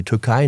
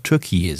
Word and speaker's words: Türkiye: Erdogan, unser türkischer Freund, er möchte ja auch, dass man über die Türkei nur noch Türkiye sagt. Also das Türkisch ausspricht.